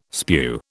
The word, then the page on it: spew: British English